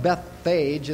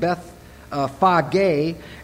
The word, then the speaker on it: Bethphage: That's actually how you pronounce that word. It's really not pronounced Bethphage, it's pronounced Bethphage.